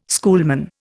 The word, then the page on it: schoolman: Indian